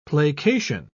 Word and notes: placation: British English

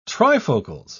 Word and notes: trifocals: British English

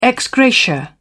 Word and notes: ex gratia: British English

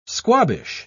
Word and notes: squabbish: British English

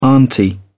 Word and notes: auntie: British English

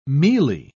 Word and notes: mealie: British English

